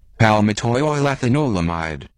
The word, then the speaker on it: Palmitoylethanolamide: So has lined up the correct pronunciation for us. – Palmitoylethanolamide.